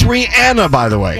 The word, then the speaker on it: Rihanna: But I pronounce it Rihanna. It's Rihanna. Ellen asked her specifically, and she answered.